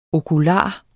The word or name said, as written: okular